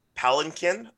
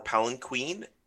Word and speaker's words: palanquin: a little game called, I try never to pronounce it verbally because everyone says it's, there's also multiple pronunciations for this one. Palanquin or Palanquin or Palanquin, depending on where you're coming from.